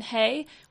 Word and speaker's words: Hay: My name is Malin Hay, which rhymes with day.